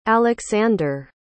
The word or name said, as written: aleksander